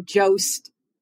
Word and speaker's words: Jost: And, you know, Jost, you know, there's a reason why his last name rhymes with roast.